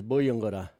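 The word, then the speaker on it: Buyungurra: Our local Mulgana word today is Buyungura.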